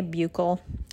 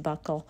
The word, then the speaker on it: buccal: Some people say buccal, it's pronounced buccal.